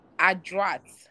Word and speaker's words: adroit: So, this word is pronounced adroit, like the French adroit.